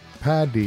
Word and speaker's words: Paddy: P-A-D-D-Y. – Am I saying P-A-T-T-Y? – Yeah, you're just Englishing it. – I just said St. Paddy. Relax, guys. It's a fuck. I said Paddy. I pronounce it as a D.